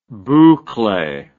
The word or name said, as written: bouclee